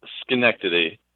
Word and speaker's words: Schenectady: It's pronounced Schenectady.